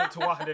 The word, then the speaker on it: Tuatha: Uh, that's at least the way that I've always heard it pronounced the Tuatha Yeah.